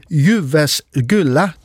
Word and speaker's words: Jyväskylä: Niels Christian Hansen, han er timelærer på det jyske musikkonservatorie og postdoc på universitetet i Jyväskylä. Det er i Finland, og det udtales præcis sådan.